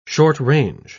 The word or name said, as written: short-range